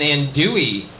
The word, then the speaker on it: andouille: Pasilla chile and andouille. Am I saying that right? – Yeah. – Those two L's are silent.